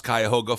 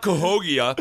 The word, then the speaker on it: Cuyahoga: I was really hoping you were going to mispronounce Cuyahoga Falls, because I know that. It's called Cuyahoga. And I was going to be like, no, it's not Cahogia or whatever you were going to say. It's Cuyahoga Falls.